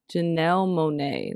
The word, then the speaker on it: Monáe: Janelle Monáe.